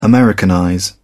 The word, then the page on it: americanize: British English